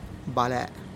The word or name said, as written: balè'